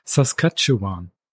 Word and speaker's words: Saskatchewan: In the years of 2011 to 13, she traveled solo more than 2,500 kilometers through the southern plains of Saskatchewan." That right pronunciation? – Yes, very good.